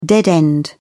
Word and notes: dead end: British English

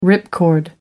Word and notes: ripcord: American English

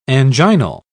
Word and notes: anginal: British English

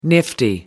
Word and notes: nifty: American English

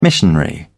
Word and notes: missionary: British English

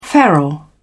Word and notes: feral: British English

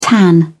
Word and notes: tan: British English

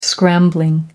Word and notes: scrambling: American English

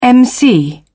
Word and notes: emcee: British English

